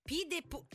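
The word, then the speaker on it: pied-de-poule: Ho detto bene, Pied Pool. – Esatto. – Pied Pool. Però non... – P. – P? – P de Pool. – Ah, Pidepool, sai che ho sempre letto Piedpool.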